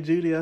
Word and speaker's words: Jeudy: with maybe the top receiver in the nation, Jerry Jeudy. I said it right here. – Ooh, okay, Jerry Jeudy is a baller.